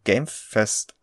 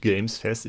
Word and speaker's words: Game Fest: jetzt mit diesem Summer Game Fest auf sich? Genau, in diesem Mal hast du es richtig gesagt. Gamefest, nicht Gamesfest.